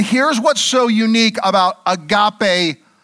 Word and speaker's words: agape: It's the Greek word agape, or agape, as it may be pronounced in the Greek.